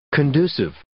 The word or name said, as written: conducive